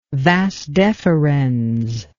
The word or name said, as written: vas deferens